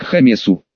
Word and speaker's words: Гомесу: Капец Гомесу», — резюмировала Анна. «Причем у нас это звучит через грубое «г», а они произносят через «х», будет звучать как «хамесу».